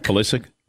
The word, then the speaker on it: Pulisic: I'll say it wrong, but that American soccer player, Christian Pulisic? – Pulisic? – Yes.